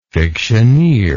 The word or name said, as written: fictioneering